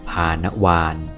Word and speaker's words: ภาณวาร: พานวานพานวานแปลว่าวาระแห่งการสวดตอนของการสวดพานวาน